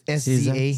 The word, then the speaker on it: SZA: How do you pronounce her name? – SZA.